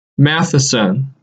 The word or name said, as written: Matthiessen